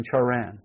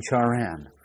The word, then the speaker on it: Haran: Footnote, Haran. The Hebrew letter beginning this name is guttural, difficult to represent by Roman letters, and difficult for English people to pronounce. It is much like the German CH.